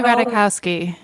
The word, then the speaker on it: Ratajkowski: Emily Ratajkowski. I think. – Yes. – I think that's how you say it. Yes.